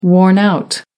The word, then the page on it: worn-out: American English